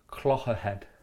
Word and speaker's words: Clogherhead: I'm, I'm currently offshore in a place called Mornington, and then I'm going to try and head to a place called um, Clogherhead. Yes. Is that yes, right pronunciation? Yes. Bang on.